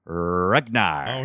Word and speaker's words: Ruggnar: R-U-G-G-N-A-R. Rugnar. – Oh, here we go. Like Ragnar, but with Rugnar.